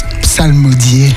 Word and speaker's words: psalmodier: Ça veut dire quoi, psalmodier? – Réciter des psaumes ou lire des psaumes. Voilà, c'est ça. Psalmodier, comme le faisait le psalmiste. C'est vrai qu'il faut bien prononcer le P, le S, le sal.